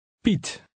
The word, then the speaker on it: Piet: The Dutch vowel combination IE sounds somewhat like the long E in feet, but it's shorter and clipped. Try to read number 6. – Piet.